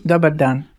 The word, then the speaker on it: dobar dan: Or do you roll the R? Dobar. – You don't roll the R. You say dobar dan.